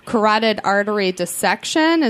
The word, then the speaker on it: carotid artery dissection: And it was a carotid artery dissection. Is that the correct pronunciation? – Yeah, that's correct.